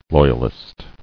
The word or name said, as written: Loyalist